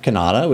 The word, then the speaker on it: Cannata: I'm at Jeff Cannata, which is spelled with two N's and one T.